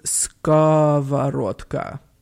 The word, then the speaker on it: skavorotka: Gavorokta? – Oh, it starts with an SK, so it's skavorotka.